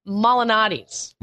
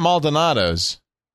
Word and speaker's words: Malinati's: It's Malinati's. – Malinati's? – Yeah. – Not Maldonado's. – No. – Because he does candy. – Lou Malinati's. – Lou Malinati's.